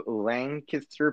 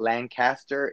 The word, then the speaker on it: Lancaster: Anyway, so we were taking a little hippity hoppity trippity choppity to Lancaster, Pennsylvania. Get it right, folks. It's not Lancaster, it's Lancaster. Like with a K-I-S-S, but that's what you pronounce it.